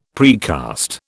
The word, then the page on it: precast: British English